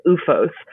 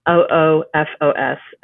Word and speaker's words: OOFOS: I think you pronounce it OOFOS, OOFOS, and they're mm-hmm.